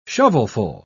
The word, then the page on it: shovelful: British English